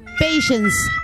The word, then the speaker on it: patience: Patience, patience. Con ese al final, patience.